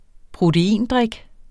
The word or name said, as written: proteindrik